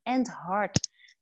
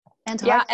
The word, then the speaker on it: Andheart: En de founder van End Heart Go. Zeg ik dat goed, – End Heart ja, End Heart.